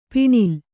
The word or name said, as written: ಪಣ್ಣೆಲೆ